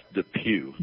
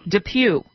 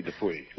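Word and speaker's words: DePuy: It's pronounced Dupuy. – Dupuy. Okay. Well, that makes sense. – Although sometimes I say Dupuy. – But it is Pew.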